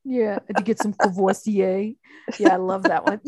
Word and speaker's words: Courvoisier: I'm putting a car payment down on Kwasi. Am I saying it right? – Courvoisier.